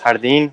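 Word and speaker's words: Jardín: I believe it's pronounced Jardin, which is a near flawless album for me.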